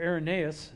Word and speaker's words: Irenaeus: the Expositor's Bible Commentary gives this evidence from Irenaeus, or Irenaeus, is how you say it, in his record of the history of the Muratorian canon.